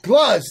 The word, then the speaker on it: Gluz: Gluz, that's it. G-L-U-Z, yeah.